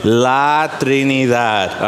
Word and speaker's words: La Trinidad: La Trinidad. All right, now you know Spanish. Good job. I know it's a hard one. You got to roll your R's on that one.